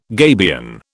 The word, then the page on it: gabion: British English